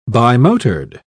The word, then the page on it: bimotored: British English